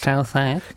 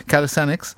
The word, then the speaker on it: Calisthenics: Calisthenics. Calisthenics.